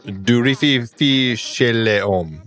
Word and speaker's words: Du rififi chez les hommes: Or du rififi chez les hommes.